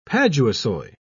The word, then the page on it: paduasoy: British English